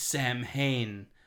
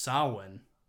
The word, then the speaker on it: Samhain: But Donald Pleasant says in Halloween 2, he's like, it's Samhain, like where all the evil demons come to Earth. And it's like, it's pronounced Samhain. Like, wouldn't you know that if you're like a studier of Halloween? – But he was a bit.